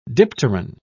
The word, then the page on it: dipteran: British English